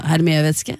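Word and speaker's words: Hermès: Det sånn det uttales? – Neida, Hermé-veske. Okay. Hermé-veske. Jo, man sier vel Hermé-veske. – Vet jo ikke dette. – Det jo et fransk merke.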